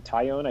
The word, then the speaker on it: Taillon: Taillon, I think is how you pronounce his name. Does not uh, sound like it looks, but I was researching. It's the word tie and the word own.